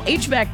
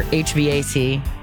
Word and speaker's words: HVAC: National HVAC Tech Day. Or HVAC. The acronym and or initialism, depending on how you say it.